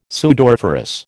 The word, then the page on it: sudoriferous: American English